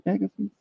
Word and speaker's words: McAfee: That's how you say his name. McAfee.